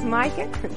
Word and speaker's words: Maaike: T-I-M. – And your name? – My name is Maaike. – Can you... – M-A-A-I-K-E.